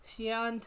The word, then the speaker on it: Shion: much how you don't pronounce it. – Yes, it is. I just Googled it. I just Googled the pronunciation. – Okay. – Shion Takeuchi, she worked on Gravity Falls, and she worked on Regular Show.